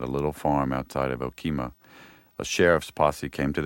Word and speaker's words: Okemah: This is from May of 1911 in Okemah, Oklahoma. I'm not sure if I'm pronouncing that correctly. – That's right, Okemah.